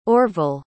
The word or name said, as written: orvil